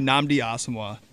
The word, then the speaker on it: Nnamdi Asomugha: Give me Nnamdi Asomugha.